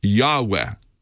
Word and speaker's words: Yahweh: It's Yahweh. That's how it's pronounced. We're not even sure what the vowels are, because when the original Bible was written, there were no vowels. But we know there's the way they normally spell it is Y-H-W-H. And we're assuming the first vowel is an A and the second is an E.